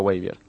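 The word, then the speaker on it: Wavier: És Weavier, como, bueno, se escribe como Xavier, però en vez de una X, una W, però se pronuncia Weavier.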